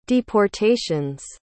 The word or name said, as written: deportations